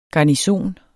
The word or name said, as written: garnison